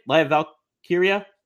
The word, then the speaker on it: Lyra Valkyria: How do you say it? Lyra Valkyria? I think, yeah. – I got it that time. – Yeah, you did.